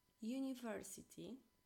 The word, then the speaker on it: university: A na przykład wyraz university, uczelnia jakaś wyższa, uniwersytet, czytamy poprzez j, czyli będzie e university.